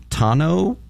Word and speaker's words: Tonneau: All right, and now we have, they have running, this is another listing, Running Bodes and Tonneau Cover. I want to make sure that I pronounce that correctly. Bodes, B-O-A-D-S, and Tonneau, T-O-N-N-E-A-U, Cover.